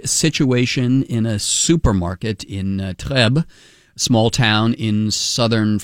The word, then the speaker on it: Trèbes: Yeah, so that's the best we can get out of this small town called Trèbes, T-R-E-B-E-S. It's in southwestern France. – Trèbes, you don't pronounce the S. It's Trèbes.